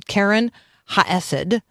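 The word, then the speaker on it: Keren Hayesod: I'm going to work on pronouncing it correctly here. Karen Ha'esed.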